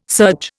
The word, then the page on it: serge: Indian